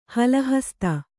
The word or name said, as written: ಹಲಹಸ್ತ